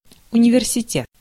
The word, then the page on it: université: France; [y.n‿y.ni.vɛʁ.si.te]